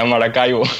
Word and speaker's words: Maracaibo: Yeah, Maracaibo.